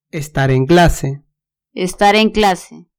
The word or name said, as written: estar en clase